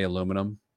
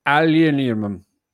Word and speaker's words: aluminium: Have we talked about on the show, Dame, how the Brits say aluminum? Aluminium? Aluminium.